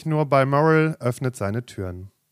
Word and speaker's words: Balmoral: B-A-L-M-O-R-A-L. – Balmoral. – Balmoral.